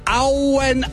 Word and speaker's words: Owen: Wer sich hier in der Region auskennt, der weiß auch, dass eure Heimatgemeinde, die Stadt Owen geschrieben wird, aber Auen ausgesprochen wird.